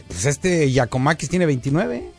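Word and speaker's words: Giakoumakis: Bueno, pues, ahorita están, acaban de firmar a Juan Cuamaquis, ahorita ya lo firmaron. – Es Giacomaquis. – Ah, Giacomaquis. – Sí, así normal. Giacomaquis.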